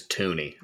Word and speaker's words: Thuny: Well, I just looked up a video of him saying his own name, which is what I prefer, and it is Thuny.